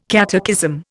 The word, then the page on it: catechism: Indian